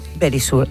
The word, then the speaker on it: Verisure: Securitas Direct es ahora Verisure. Eso aprendido, yo creo que ya lo sabéis. Verisure, ay, que me lo dice, eso es. Bueno. – Ahí lo digo bien.